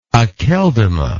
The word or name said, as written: akeldama